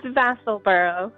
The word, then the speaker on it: Vassalboro: All right, our first call today comes from Vassalboro, Maine. I hope I got that right. Vassalboro, Maine. Hey, did I get that right? Good afternoon. Forgive me for butchering that. – It's Vassalboro.